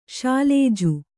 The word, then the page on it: ಶೈಲಜೆ: śailaje